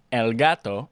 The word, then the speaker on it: gato: Annapurna recently released a game about a cat, El Gato en Español. Rhymes with avocado, el gato, abogado.